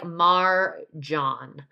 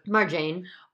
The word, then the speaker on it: Marjane: Just wanted to correct her name's pronunciation. It's kind of like Marjan. – Marjan. – Marjan. So we were talking about Marjan Satrapi. Satrapi, yeah. – How did we pronounce it? – Marjane. – Oh, okay. – And who wrote the Persepolis graphic novels, and she's just also a badass. And yeah, so we were pronouncing it incorrectly.